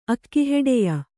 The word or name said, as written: ಅಕ್ಕಿಹೆಡೆಯ